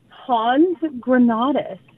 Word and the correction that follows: hand grenades: and everybody was so perplexed, like, what is she trying to say? And finally, somebody looks at the card and says, Matt, it's hand grenades.